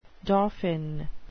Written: dauphin

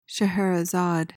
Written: Scheherazade

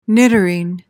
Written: niddering

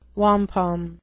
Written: wampum

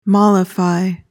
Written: mollify

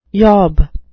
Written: yob

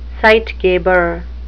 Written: zeitgeber

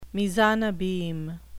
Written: mise en abyme

